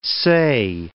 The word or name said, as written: say